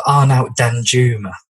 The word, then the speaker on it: Arnaut Danjuma: And also, I know Arnaut Danjuma. That's how you say his name as well.